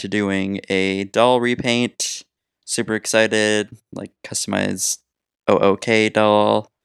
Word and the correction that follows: OOAK: Or OOAK doll. Like, they pronounce it oak.